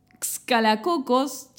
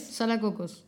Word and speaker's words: Xcalacocos: Salacocos. Salacocos. Se escribe XC Alacocos, Salacocos. No, no tengo la más puta idea de cómo se pronuncia. Es más relajado, Salacocos. Bueno, listo, Salacocos. Que es en Quintana Roo.